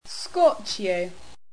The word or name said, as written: scorchio